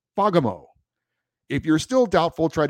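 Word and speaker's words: Fagemo: I'm certain because I have a friend from Sweden who told me how to pronounce it, that it's pronounced Fagamo, with the accent on the first syllable. The A is pronounced like an O in the words jolly and folly.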